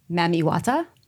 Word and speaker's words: Mami Wata: Mami Wata. Did I say it right this time? – Yes, you did.